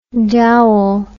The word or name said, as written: Dowell